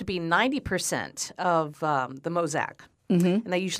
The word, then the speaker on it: Mauzac: And you're saying that if this one, it means it's the Mauzac wine? Mauzac? Yeah. Mozac. That's, Mozac. That's how they were saying it there. I it, know it's spelled, um, how's it spelled? M A U Z A See?